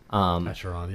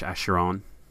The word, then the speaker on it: Acheron: Acheron. Is that how you say it? – Acheron, yeah. – Or if you're French, Acheron.